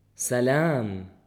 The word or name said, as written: سلام